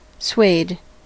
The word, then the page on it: suede: American English